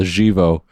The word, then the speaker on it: Živo: I'm going to pronounce it like the Yugoslavian style, Živo.